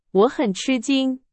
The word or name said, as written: 吃惊